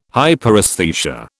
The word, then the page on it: Hyperesthesia: British English